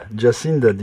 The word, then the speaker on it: Jacinda: Eylül'de Yeni Zelanda'da seçimler olacak ve 1 Ağustos'ta İşçi Partisi'nin başına seçilen 37 yaşındaki kadın Jacinth, nasıl telaffuz edilir bilmiyorum, Ardern soyadı. – Jacinda diye okuyor.